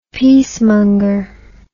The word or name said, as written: peacemonger